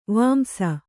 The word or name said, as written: ವಾಂಶ